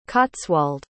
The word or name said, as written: cotswold